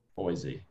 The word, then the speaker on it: Boise: Boise. – He puts a Z in there. – Yeah, I mean, that's how it's pronounced to me.